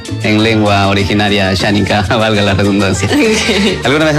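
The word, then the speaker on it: Asháninka: A ver, Ameyetari Ashaninka. – La pronunciación es Ameyetari Ashaninka.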